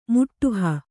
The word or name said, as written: ಮಾಡುಹ